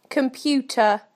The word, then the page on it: computer: British English